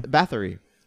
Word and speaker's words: Bathory: Elizabeth Bathory. – Yeah, good pronunciation, by the way. I want to say it's Bathory, but I don't really know.